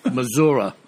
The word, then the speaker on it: Missouri: yeah, but you said mutilations correctly, but you uh, mispronounced Missouri. All right, so remember that. It's Missouri.